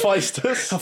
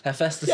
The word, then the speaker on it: Hephaestus: Oh, I got Hepatitis, or whatever his name is. – Hephaestus. – Hephaestus? – Hephaestus, yeah, I believe, yeah, is the pronunciation.